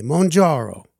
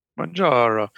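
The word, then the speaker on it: Mounjaro: that we pronounce in a very Italian way, Mongiaro. – Mongiaro.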